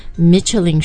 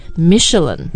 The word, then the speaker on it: Michelin: 就除了 multi-layer 很多层以外，还很有商业头脑。对，好。那它的发音首先因为法语里面嘛这个和英语发音规律还是不一样所以它不念 Michelin 是 Michelin